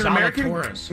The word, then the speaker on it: Zalatoris: We don't even know that his name is pronounced Zalatoris.